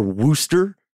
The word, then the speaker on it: Worcester: or Wooster,